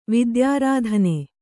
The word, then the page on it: ವಿದ್ಯಾರಾಧನೆ: vidyārādhane